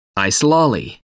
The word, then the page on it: ice lolly: American English